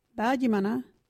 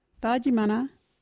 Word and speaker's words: thaajimanha: I don't like the word is Dajimana. What is Dajimana? Dajimana is opening out or swearing. You can use Dajimana in this sentence. Are you that Dajimana? Dajimana is spelled THAAJIMANHA.